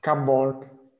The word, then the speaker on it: cupboard: Cupboard. – Cupboard. Yes, cupboard. You'd imagine it to be two B's perhaps in the middle, but it's literally from cupboard.